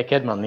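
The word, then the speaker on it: Cædmon: Is it Kedmon? – Yeah, Kedmon. Yeah.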